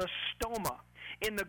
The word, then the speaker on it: stoma: Now the word mouth is the Greek word stoma, S-T-O-M-A. We would probably pronounce it in English stoma.